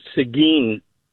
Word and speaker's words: Seguin: The little town is actually pronounced Seguin, Texas.